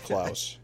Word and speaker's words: Clowes: P.S. I like to pronounce Clowes the same way you do, but it actually sounds like house.